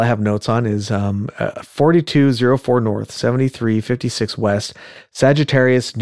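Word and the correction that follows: Saugerties: That's not Sagittarius. That's Sagerties.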